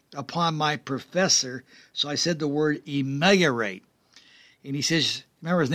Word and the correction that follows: ameliorate: He says, you're pronouncing it wrong. And guess what, folks? He was right. It's ameliorate. It means to improve.